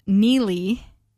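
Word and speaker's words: Neagley: Apparently, it's pronounced Neely. There's a G in there. It's a silent G for some reason.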